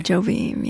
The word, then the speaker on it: Jobim: Did I say his name right? – Jobim, yeah.